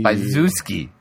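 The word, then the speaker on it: Zbyszewski: Byzuski. – Paul Byzuski. All right? Everybody pretend like you heard me say it right the first time. – Yeah, the leading Z is silent.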